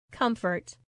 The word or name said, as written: comfort